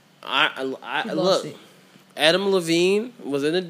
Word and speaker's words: Adam Levine: No, we're going to talk about Adam Levine and how he wild as shit. – Wait, say his name again? – Adam Levine. – His name is Adam Levine.